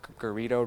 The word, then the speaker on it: Garrido: Garrido rhymes with burritos.